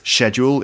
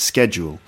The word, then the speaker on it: schedule: In the UK, we spell it T-H-E-A-T-R-E, a bit like the French. Or the pronunciation of a word. For example, the way we pronounced schedule. In the UK, it's schedule. In America, it's schedule. Although more and more people in the UK, as far as I can tell, are starting to say schedule, much to some people's great displeasure.